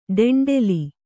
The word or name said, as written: ಡೆಂಡೆಲಿ